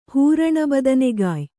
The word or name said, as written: ಹೂರಣಬದನೆಗಾಯ್